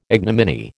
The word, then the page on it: ignominy: American English